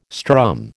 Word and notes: strum: American English

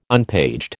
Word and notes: unpaged: American English

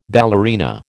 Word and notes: ballerina: American English

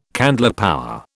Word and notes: candlepower: British English

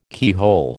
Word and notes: keyhole: American English

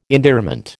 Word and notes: endearment: American English

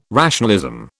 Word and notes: rationalism: British English

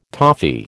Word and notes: toffee: American English

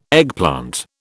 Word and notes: eggplant: British English

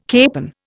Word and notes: capon: Indian

